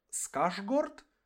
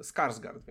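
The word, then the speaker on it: Skarsgård: Скаржгорд? Ні, це як... Це щось дивне. Скарсгард він.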